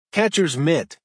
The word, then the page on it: catchers mitt: American English